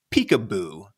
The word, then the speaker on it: PCAOB: how to pronounce the acronym. In fact, I've heard that in the early days, folks around DC used to refer to the agency as Peekaboo, which maybe makes some sense.